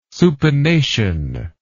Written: supination

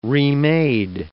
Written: remade

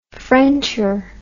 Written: frencher

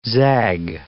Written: zag

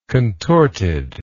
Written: contorted

